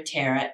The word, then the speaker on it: Terah: So that's his name, Terah. It's spelled T-E-R-A-H.